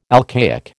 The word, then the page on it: alcaic: American English